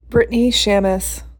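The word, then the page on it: Brittany Shamess: English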